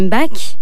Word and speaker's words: Bach: Je vais vous parler de la rencontre étonnante entre la musique du compositeur allemand Jean-Sébastien Bach, pour la prononciation française, et un instrument qui a été inventé presque 2 siècles après sa mort, l'accordéon.